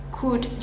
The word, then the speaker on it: could: Sama kayak could juga. Bukan could, L-nya nggak usah dibaca, langsung aja could.